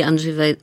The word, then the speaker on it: Andrzej: Oui, Andrzej e, Wajda, je, je, le prononce en polonais. – Oui, oui, c'est moi qui le prononce à la française.